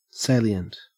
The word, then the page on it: salient: Australian English